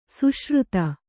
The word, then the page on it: ಸುಶ್ರುತ: suśruta